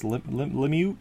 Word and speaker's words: Lemieux: What is it? Lemieux? – Lemieux. Lemieux. It's French, dude.